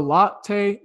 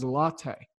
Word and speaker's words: Lotte: I was saying Lot. It is spelled L-O-T-T-E. but it is a latte. It just kind of, you know, sounds like a coffee drink to me.